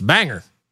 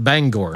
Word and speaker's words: Bangor: And if you are in Maine, that is how you pronounce it. It's Bangor. Not Bangor.